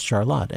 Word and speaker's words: Charlotte: It's spelled the way Charlotte is, but it is pronounced Charlotte. And that's one of those words, you know, like when you have Milan and Milan. If you're from central Michigan, if people say Charlotte, they know you're local.